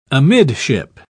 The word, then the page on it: amidship: British English